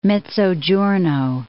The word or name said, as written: Mezzogiorno